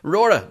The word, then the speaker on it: Rura: Rora, the massive Celtic folk band that is... Rora are just incredible. In case you don't know how to pronounce R-U-R-A, I think it's Rora, R-U-R-A, Rora.